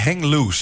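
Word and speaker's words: Heng Loose: Uh, eh, eh, hang loose.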